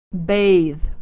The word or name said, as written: bathe